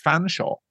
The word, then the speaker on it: Featherstonehaugh: You know, I mean, Re- you for years I didn't know that the, the English name Featherstonehaugh is pronounced Fanshawe.